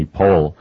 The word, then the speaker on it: Powell: I've had a long an appointment with a writer named Anthony Pohl. I'm pronouncing it that way only because he himself said it rhymes with Lowell, P-O-W-E-L-L.